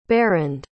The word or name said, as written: barend